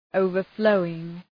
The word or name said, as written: overflowing